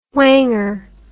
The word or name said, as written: whanger